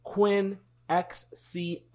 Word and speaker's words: Quinn XCII: It is Quinn XCII, as in Roman numerals.